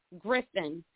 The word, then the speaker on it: Griffin: N-A-D-E-R-A-H, Griffin, G-R-I-F-F-I-N, and that's F-F as in family, family.